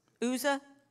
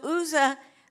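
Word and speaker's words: Uzzah: The guy that's steadying the cart that is in charge of driving it, I don't know if we call him Uza or Uzza. Does anybody know the correct pronunciation? Uza? Okay. So Uza, the ark is getting unsteady.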